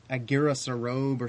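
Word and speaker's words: Aguirresarobe: How do you pronounce this guy's? Is it Aguirre Sarobe or something like that? – Yeah, like that.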